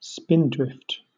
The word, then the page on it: spindrift: British English